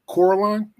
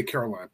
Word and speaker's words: Coraline: I'm, I'm probably going to say his name wrong again, but it's number 23, Coraline. – Did I say the word? Coraline, yeah. yeah. Cor, Cor, uh, uh sorry, I'll say Caroline, but Coraline.